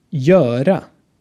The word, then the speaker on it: göra: Der dritte Laut ist wieder ein langer Laut, der vor R vorkommt göra göra ein deutsches Beispiel Hörner und dann haben wir einen Laut ohne deutsche Entsprechung ein kurzes Ö vor R.